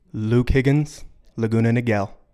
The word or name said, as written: Luke Higgins